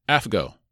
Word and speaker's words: AFGO: The fourth acronym, AFGO, is pronounced AFGO. It's like Aflac without the lack.